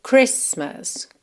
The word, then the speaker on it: Christmas: Só que com essa palavra aqui, especialmente, a gente realmente não pronuncia a letra T. Então, você vai dizer Christmas, Christmas. Já a palavra anterior,